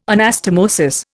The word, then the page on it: anastomosis: Indian